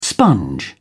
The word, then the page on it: sponge: British English